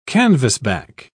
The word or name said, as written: canvasback